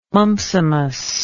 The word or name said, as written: mumpsimus